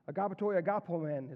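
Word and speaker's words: agapetoi agapomen: Agapatoi agapomen is how you say that in the Greek.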